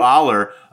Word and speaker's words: Allar: And I see Drew Alar there we go. Uh, first mispronunciation. Take a drink. Uh, Drew Drew Allar.